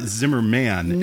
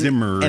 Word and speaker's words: Zimmern: the, the funniest kind of because uh, it's not Zimmerman. – No, it's and, Zimmern. and it's Zimmern, and it's hard to say.